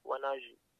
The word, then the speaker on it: Wonaji: And as I said again, to find me, my name is spelled Wonaji, and it's pronounced Wonaji.